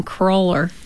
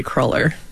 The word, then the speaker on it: cruller: Is it Crawler or Cruller? – Yeah, okay. – Purists will say Cruller. – Okay, well, hopefully. Correct me if I ever get it wrong. So I think it's just personal preference. I'm not going to police anybody on pronunciation of Crawlers.